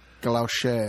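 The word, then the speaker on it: galocher: It's called galoucher. – Well, shouldn't she pronounce that the French way? – Galoche. – Galoche. – It's galoche.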